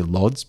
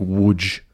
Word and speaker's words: Łódź: I'd just look at it and say Lodz, but, you know, I would say Lodz too, but I'm sure that would be wrong. – I think Łódź is how it's properly pronounced in Polish.